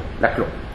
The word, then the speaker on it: Laclau: how do you pronounce it? French? – No. – Laclau, okay.